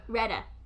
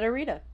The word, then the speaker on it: Retta: Retta. – That's just another R. – Retta.